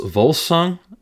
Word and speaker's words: Volsung: vols volsung is how you, i think you pronounce it V O L U, uh, sorry, V O L S U N G, Volsung.